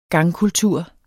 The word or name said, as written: gangkultur